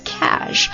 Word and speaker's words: cache: Cash, 容易读错的一个单词， Cash, 也就是听起来像现金那个单词，它跟现金那个单词读音是一模一样的， Cash, C-A-C-H-E, 一起来看这个单词的意思。